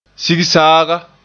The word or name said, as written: sigsaaga